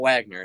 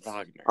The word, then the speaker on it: Wagner: It's not Wagner, it's Wagner.